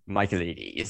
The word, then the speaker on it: Michaelides: So I looked up an interview, a podcast interview, and the interviewer said Michaelides.